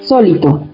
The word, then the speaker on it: sólito: Es una palabra que se escribe así, sólito, sólito, con tilde en la primera O porque esa es brújula, sólito.